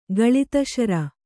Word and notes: ಗಳಿತಾಶ್ರು: gaḷitāśru

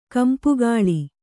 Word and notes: ಕಂಪುಗಾಳಿ: kampugāḷi

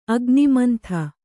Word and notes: ಅಗ್ನಿಮಂಥ: agnimantha

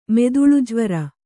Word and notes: ಮಿದುಳುಜ್ವರ: miduḷu jvara

